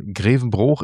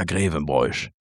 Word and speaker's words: Grevenbroich: Aber Grevenbroich ist Grevenbroich. Und ja, es ist sogar so, dass ja sogar Horst Schlemmer sagt das ja falsch, ne? Der sagt ja Grevenbroich.